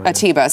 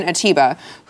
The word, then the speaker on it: Atiba: Who, am I saying that right? – Atiba. – Simon Atiba,